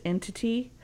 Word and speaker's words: NTT: They were also promised, oh, this really cool brand new gaming engine they were going to be using called NTT, pronounced Entity.